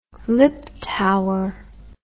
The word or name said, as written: liptauer